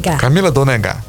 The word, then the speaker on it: Camila Dônega: Já tem isso acordado com a Camila Dônega, quem acompanha. Falei certo? – Camila Dônega. Isso.